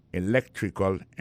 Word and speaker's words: electrical: I majored in electrical engineering. Electrical engineering. Ça veut dire accent tombé dans l'électrical, là, sur le deuxième syllabe là. Electrical engineering. C'est sur le deuxième syllabe là. Elect là, plus appuyé.